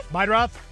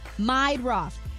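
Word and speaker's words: Meidroth: Chase Myrdah? The White Sox guy? – Meidroff. – How do you say that? – Meidroff.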